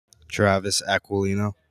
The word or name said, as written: Acquilino